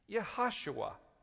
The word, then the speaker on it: Yehoshua: I just pronounced it, Yehoshua.